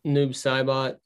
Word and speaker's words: Noob Saibot: In the Mortal Kombat saga, Bin-Han was killed and resurrected as Noob Saibo. Am I saying that right? – Noob Saibot. I think that's how my friends always said it, but that was Logan, West Virginia friends, Noob Saibot.